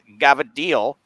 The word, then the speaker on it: cavatelli: So on Christmas Eve, my grandmother would make linguine with clam sauce, a white sauce, and then she would make cavatelli, which we pronounce as gavadil,